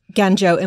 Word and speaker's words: Guangzhou: So I knew China had rare earth capacity, but I didn't realize it also has the tungsten capital of the world in Guangzhou.